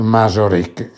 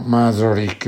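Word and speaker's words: Mazauric: Giacobini, Giacobinisme, e nel dizionario Robespierre è Claude Masoric. Si scrive M come Maria, A come Antonia, Z come Zanzibar, A come Amore, U come Uganda, R come Roma, I come Imola, C come Castoro. No? Masoric, l'abbiamo già trovato, non vi ho mandato un.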